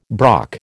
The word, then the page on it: brock: American English